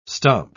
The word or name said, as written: stomp